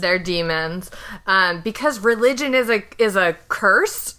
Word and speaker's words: daemons: It's just pronounced demons in the same way that Philip Pullman demons are, which is shenanigans all around.